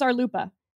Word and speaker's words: RLUIPA: RLUIPA, how do you pronounce RLUIPA? – I usually say RLUIPA.